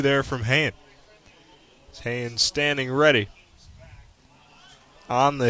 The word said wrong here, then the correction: Hayne: Excuse me, that's pronounced Hine.